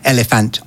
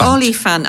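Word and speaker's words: Olyphant: Have I said that right? Elephant. Oliphant. – Oliphant.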